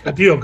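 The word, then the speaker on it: Cattiaux: Y luego yo creo que cuando hablemos de, Luis Catió, bueno, no, sé pronunciarlo, yo sé que... – Catió, Catió.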